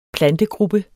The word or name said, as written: plantegruppe